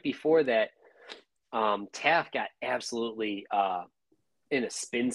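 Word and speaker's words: Taaffe: Is it Taffy? Is that how you say his name? – I think it's Taff. Taff.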